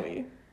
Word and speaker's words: Bowie: It is Bowie, right? Like, I'm not. – Yeah, okay. Bowie. I always get so, like, worried that I'm saying names wrong.